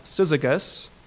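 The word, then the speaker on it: syzygus (σύζυγος): This word, companion, is a Greek word, pronounced something like syzygus, that literally means a yoke fellow.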